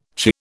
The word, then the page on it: chirr: British English